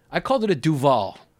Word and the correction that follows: Duvel: Hmm. – But it's called Duvel, according to her.